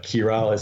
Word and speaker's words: Chiral: They have a brand called Chiral, which I know no one will pronounce that way because it's written Chiral. but Kirale is how they pronounce it in Japanese, so I have to do that.